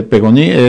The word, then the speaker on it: Peyronie: De Peyronie es como se pronuncia, yo es que lo he visto en Google cómo se pronuncia,